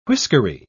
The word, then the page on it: whiskery: British English